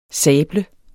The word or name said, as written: sable